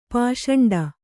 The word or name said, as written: ಪಿಸಾಂಟ